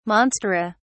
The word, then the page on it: monstera: British English